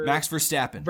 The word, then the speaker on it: Verstappen: Max Verstappen.